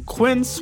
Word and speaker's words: Quince: Today Explained support today comes from Quince, which rhymes with since but is spelt with a Q-U.